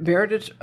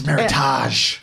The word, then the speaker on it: Meritage: Yeah, we said Meritage rhymes with heritage. And it's Meritage.